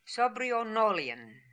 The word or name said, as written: Sobrionolian